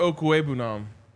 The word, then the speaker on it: Okwegbunam: That's all about Albert Okwegbunam. – No. Albert Okwegbunam. – Whatever, man. – That's easy, he says, and then butchers it. – But I have like one like syllable pronounced wrong.